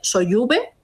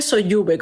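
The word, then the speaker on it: Sollube: Esto siempre lo, o sea, lo voy a pronunciar mal, que me perdonen los vizcaínos, porque es el monte Soyube. – No lo sé. – Se escribe Soyube con doble L pues y con B.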